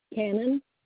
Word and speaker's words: Cannon: Cannon. – Cannon. – C-A-N-N-O-N.